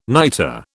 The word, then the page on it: niter: British English